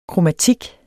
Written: kromatik

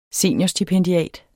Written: seniorstipendiat

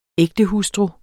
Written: ægtehustru

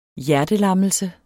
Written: hjertelammelse

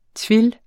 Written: twill

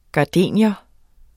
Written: gardenia

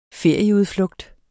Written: ferieudflugt

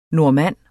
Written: normanner

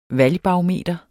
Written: valgbarometer

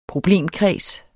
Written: problemkreds

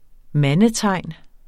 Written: mandetegn

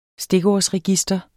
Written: stikordsregister

